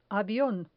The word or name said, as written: avion